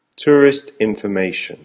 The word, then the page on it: tourist information: American English